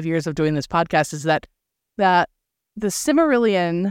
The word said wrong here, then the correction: Silmarillion: am I saying that right? Oh my God. – Silmarillion.